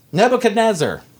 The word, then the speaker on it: Nebuchadnezzar: Daniel 3 says, King Nebuchadnezzar, did I pronounce that right, John? – Yes.